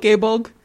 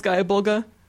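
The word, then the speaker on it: Gáe Bulg: But no one says that. Most people call it Gaybulg. It's pronounced Gaia Bulga.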